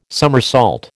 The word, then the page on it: somersault: American English